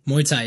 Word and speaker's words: Muay Thai: And yet now, eh, let me say if I pronounce this correctly, Muay Thai. – Muay Thai, yes.